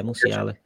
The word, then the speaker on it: Musiala: It's right, I'm going to butcher this name, Musiala at Bayern Munich. Is that right, Musiala? Yeah, Musiala. Musiala, yeah or oh, Pedri from Barcelona.